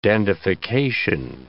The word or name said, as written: dandification